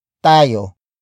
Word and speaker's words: tayo: Hinding hindi tayo iinom.